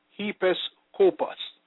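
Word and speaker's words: episkopos (ἐπίσκοπος): The Greek word for bishop is episkopos, pronounced episkopos, meaning superintendent, officer in general charge of a or the church or overseer.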